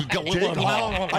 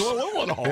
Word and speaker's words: Gyllenhaal: Did you say Jake Gyllenhaal? – Gyllenhaal. I can't say his name. – Jake Gyllenhaal. – Don't say it again. – You don't let them tell you how to pronounce things. – Thank you. – That's what makes you dangerous. – It's Gyllenhaal. – Yeah. Jake Gyllenhaal.